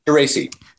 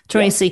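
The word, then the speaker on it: Türeci: Um, Professor Sahin and his wife, Dr. Tarasi. am I saying that correctly? – Tracy. – Tracy yeah.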